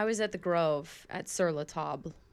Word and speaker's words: Sur La Table: I always say, that's Sur La Table over there. – Oh. I don't. – Guys, it's spelled like table. It's Sur La Table.